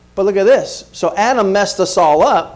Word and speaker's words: Adam: Or if you want to pronounce it correctly in the Hebrew, it's Adam.